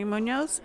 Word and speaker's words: Munoz: Tammy Munoz, M-U-N-O-Z.